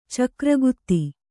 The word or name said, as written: ಚಕ್ರಗತಿ